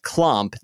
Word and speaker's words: CLOSMP: Listener Megan recommends Milkmaids or CLOSMP, which stands for Collective Listeners of Spilled Milk Podcast, which is pronounced clump. The S is silent.